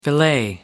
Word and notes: filet: American English

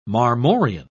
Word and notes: marmorean: British English

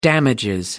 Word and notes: damages: British English

